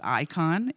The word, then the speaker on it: eikon: In the Greek, there's a couple ways to talk about image or likeness, but the Greek word that Paul specifically used is pronounced icon. Interesting. E-I-K-O-N is in the Greek, but so it's where we get our natural word, our more modern word icon.